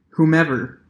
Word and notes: whomever: American English

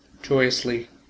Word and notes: joyously: American English

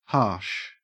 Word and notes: harsh: Australian English

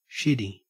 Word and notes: shitty: Australian English

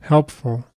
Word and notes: helpful: American English

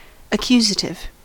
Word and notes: accusative: American English

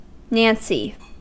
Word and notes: nancy: American English

